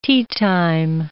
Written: teatime

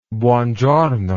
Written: buongiorno

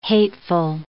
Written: hateful

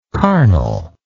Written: carnalize

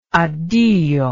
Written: addio

